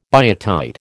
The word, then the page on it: biotite: American English